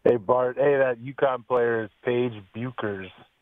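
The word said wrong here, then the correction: Bueckers: Paige Buchers. – It's pronounced Beckers. – Paige Beckers. – Beckers.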